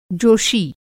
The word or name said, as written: जोषी